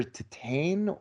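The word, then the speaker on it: Titane: It's the title that that annoys, but it's either Titan or Titan. Yeah, it's Titan with an E, whatever.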